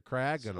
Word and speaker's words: Cryogonal: Cryogonal. Yeah. So, again, the second syllable is the emphasis there. Cryogonal.